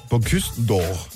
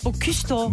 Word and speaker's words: Bocuse d'Or: Bocuse d'Or. – Bocuse d'Or.